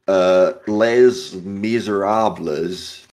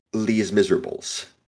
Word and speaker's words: Les Miserables: Is that they didn't know how to pronounce French. So they say the word Les Miserables and just make up their own ways to pronounce it. And they call it less miserable. The one that becomes really popular is Lee's Miserables, because they are also under General Lee.